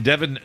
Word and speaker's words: Devon: Rhymes with seven. – Spencer and Devon Shaving Cream in a sagebrush or spice.